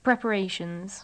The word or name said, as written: preparations